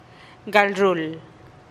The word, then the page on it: gal ruul: TdVZ